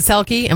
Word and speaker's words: Selke: Is it Selke? Am I saying that right? – You are.